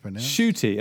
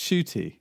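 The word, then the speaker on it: Ncuti: Shooty, am I pronounced Shooty? – Yeah, apparently.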